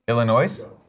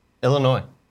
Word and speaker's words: Illinois: Why are you saying the S? – What? – Illinois? Isn't that how you say it? – Illinois.